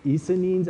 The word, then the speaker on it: Essenes: Under Judaism were Pharisees, Sadducees, Zealots and Essenes.